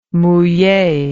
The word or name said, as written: mouille